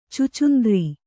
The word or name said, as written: ಛುಚ್ಚುಂದರ